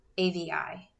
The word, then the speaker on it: Avi: A V I.